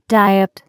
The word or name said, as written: diet